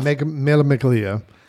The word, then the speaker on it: Mille Miglia: As they get ready to launch the Mille Miglia, I hope I said that right. – Close enough. – Mille Miglia, which is a 1,000-mile race across Italy that...